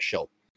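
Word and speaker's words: Shull: Sorry, I mispronounced his name. It's Rhett Schill.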